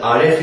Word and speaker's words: Aleph: Das Aleph ist eben kein A, sondern es ist immer, haben wir gesagt, dieser Stimmansatz oder dieser neue Stimmansatz.